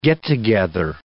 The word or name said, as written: get-together